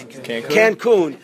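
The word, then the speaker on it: Cancún: Cancun.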